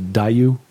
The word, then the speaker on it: Diaoyu: China calls them the, I'm not going to pronounce this correctly, the Diaoyu. D-I-A-O-Y-U.